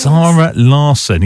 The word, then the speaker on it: Zara Larsson: It's Zara Larsson.